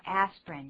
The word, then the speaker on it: aspirin: Excuse me, I was just going to say aspirin, 本来是有三个音节的 aspirin, 可是我们现在大部分只念成两个音节 aspirin, 第一个 I 根本没有念出来，念作 aspirin 就好了。